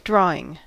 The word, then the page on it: drawing: American English